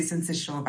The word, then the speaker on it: syncytial: Am I pronouncing the name of this virus properly? Respiratory syncytial virus? – Firstly, good evening and thank you for having me. You're actually, that's actually how you pronounce it.